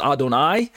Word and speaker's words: Adonai: It's pronounced as Adonai, and it's a title reserved exclusively for God.